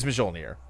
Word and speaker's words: Mjolnir: he could use Mjolnir. – Mjolnir, by the way. – Mjolnir. Is that how you say it? – Yeah, you don't do the J sound.